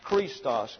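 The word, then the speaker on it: Christos (Χριστός): And that's an I-S-T-O-S. Christos. And why is it Christos? Because the I sounds like a long English E. And the key, the C-H, gets a K sound. Christos. The O here gets a short A sound.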